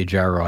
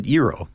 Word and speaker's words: gyro: So in this case, it would be gyro, right? I mean, you say gyro, I believe, when you're talking about something like this, like gyroscope, a gyroplane. Yeah. Okay. But in the food sense, pronounce it gyro, right? – Yeah.